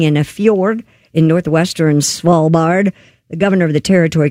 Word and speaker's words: Svalbard: In Norway's Svalbard? Is that how you say that? – Svalbard.